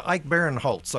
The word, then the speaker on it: Ike Barinholtz: It's Ike Barinholtz, I believe is how it's yes.